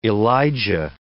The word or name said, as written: Elijah